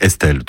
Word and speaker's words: ESTL: Alors faites pas comme moi, hein, si vous voulez pas passer pour un idiot, l'appelez pas E-S-T-L, c'est Estelle, tout simplement. Mais ça s'écrit E-S-T-L.